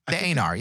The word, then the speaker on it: Aenar: So I'm hoping they do something special with the Aenar. Is that how you pronounce this subspecies? – Yes. The Aenar, yeah.